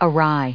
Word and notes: awry: adjective

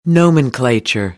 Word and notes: nomenclature: American English

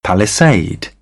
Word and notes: palisade: British English; ɪ

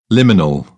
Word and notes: liminal: British English

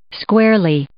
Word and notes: squarely: adverb

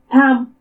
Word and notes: T'amu: Purépecha

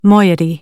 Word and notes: moiety: American English

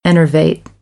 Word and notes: enervate: American English; ɚ - ə

